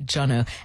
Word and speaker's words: Joneaux: His name is spelled J-O-N-E-A-U-X, all right, Jono.